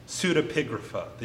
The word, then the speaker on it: pseudepigrapha: That's pseudepigrapha. The emphasis is on the pig in the middle of that word.